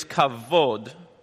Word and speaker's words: kavod: And just as a reminder, you know, glory, that word is in Hebrew is kavod. I mean, you want to spell it in English? It's K-A-V-O-D. But you can spell it however you want because it's a Hebrew word and it's not English. So it's kavod.